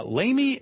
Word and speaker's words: Lamy: Mark in Lamy, New Mexico. Am I pronouncing that right, Mark? – That's right, sir.